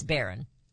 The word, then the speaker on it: Barron: And then when he got the primary the first time, when he was nominated at the RNC, and they brought Byron up. I'm sorry, I said his name wrong. It's Barron.